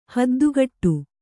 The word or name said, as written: ಹದ್ದುಗಟ್ಟು